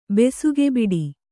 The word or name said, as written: ಬೆಸುಗೆಬಿಡಿ